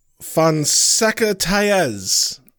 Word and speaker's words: Fonseca-Taez: Our executive Doodle-Doos are Liam Neville, Monica Fonseca-Taez. I had to write that phonetically. – You got it.